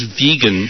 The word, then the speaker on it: vegan: I went to our research department. It turns out, actually, it is vegan. That's the British pronunciation. It's a term invented by a British guy in the 1940s.